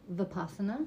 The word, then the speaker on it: Vipassana: Vipassana.